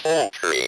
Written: poultry

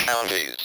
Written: county's

